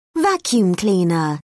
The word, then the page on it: vacuum cleaner: British English